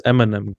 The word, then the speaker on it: Eminem: Eminem, genau, das ist die richtige Aussprache.